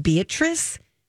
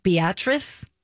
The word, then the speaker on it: Beatrice: It's spelled Beatrice, but it's pronounced Beatrice.